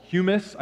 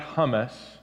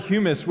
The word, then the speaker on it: humus: It's originally from this word called humus. I think that's how you pronounce it. It's not hummus. I knew it couldn't be hummus, because hummus is the, you know, Mediterranean dip that we like to dip our pita in.